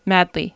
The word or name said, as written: madly